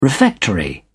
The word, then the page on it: refectory: British English